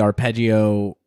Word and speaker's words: Arpeggi: This is Weird Fishes slash Arpeggi. Do I have that pronunciation right, or is it Arpeggi? – Uh, I've heard it both, but I think in in our uh, arpeggi is correct.